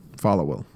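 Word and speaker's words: Followill: Falwell.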